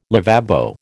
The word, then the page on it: lavabo: American English